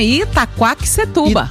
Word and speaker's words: Itaquaquecetuba: Como é que fala? – Ô, Fih, é assim mesmo, Itaquaquecetuba.